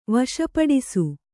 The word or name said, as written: ವಶಪಡಿಸು